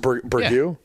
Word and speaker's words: burgoo: Burgoo? – Yeah.